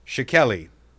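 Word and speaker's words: Schickele: Shakeli. – Oh, yeah, no, that's fair. No, that's pretty close to actually how his last name is pronounced.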